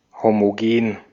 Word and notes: homogen: /ˌhoːmoˈgeːn/

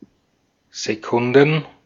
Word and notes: Sekunden: /zeˈkʊndn̩/

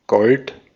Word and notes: gold: [ɡoʊld]; American English

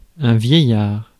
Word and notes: vieillard: France; [vjɛ.jaʁ]

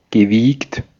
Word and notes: rusé: France; /ʁy.ze/

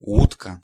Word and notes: schip: [sχɪp]; /sxɪp/

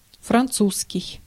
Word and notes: French: [ˈfɹɛntʃ]; /fɹɛnt͡ʃ/; /frɛnʧ/

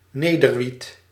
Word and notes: weed: /wiːd/; [wiːd]

